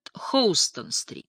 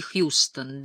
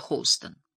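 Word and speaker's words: Houston: а потому что он располагался к югу от Хоустон-стрит. Название этой улицы только приезжие произносили как «Техасский Хьюстон». Для обитателей Нью-Йорка она всегда была Хоустон.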